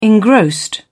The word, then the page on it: engrossed: British English